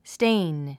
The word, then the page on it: stain: stéin; ステェイン